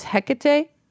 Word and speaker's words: Hecate: In Greece, we have the Greek goddess Hecate. I think I'm saying that right. – You are.